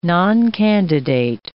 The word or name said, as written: noncandidate